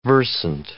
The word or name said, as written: versant